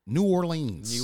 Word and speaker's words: New Orleans: According to this white person I worked with at Whole Foods, they're like, it's pronounced Nolens. So, yeah, okay. That's how you pronounce it when you have the terrible accent. – Yeah. – Yeah, if you're an English speaking person, you say New Orleans.